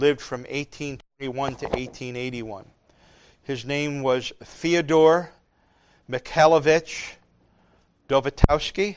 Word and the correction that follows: Dostoevsky: How do you say his last name? Well, anyway. He was Dostoevsky, okay?